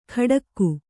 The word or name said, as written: ಖಡಕ್ಕು